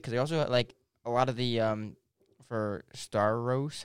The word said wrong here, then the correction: Starro: how do you say that name? – Starro.